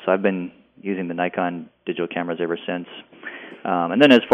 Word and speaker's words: Nikon: I use, as far as on the photography side, I use Nikon, as it's actually pronounced, gear.